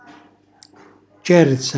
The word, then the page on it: cerza: calabrese